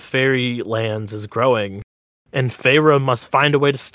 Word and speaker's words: Feyre: I've said her name so much, it's just in there. Yeah, it's pronounced Feyre.